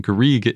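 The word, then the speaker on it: garrigue: Garrig is a type of drought-tolerant shrubland, which is common in the Mediterranean. And it's pronounced Garrig in French or Garriga in Occitan.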